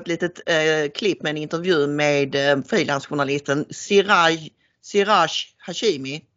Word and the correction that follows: Hashmi: Uttalar jag det rätt nu? Hashmi, ja just det.